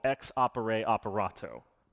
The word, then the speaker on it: ex opere operato: In liturgical theology, I'm not going to say this right, sorry, Max, there's a concept called ex opere operato.